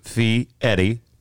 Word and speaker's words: Fieri: If you look, how do you pronounce Fieri in Italian, which is what it is. Fieri pronounced Fieri. With the E-R-I, trilled Italian style, for anyone wondering why the R sounds more like a T or a D, was born Guy Fieri, F-E-R-R-Y.